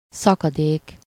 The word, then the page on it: szakadék: /ˈsɒkɒdeːk/